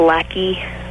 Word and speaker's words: Lacey: Okay, actually, it's pronounced lackey.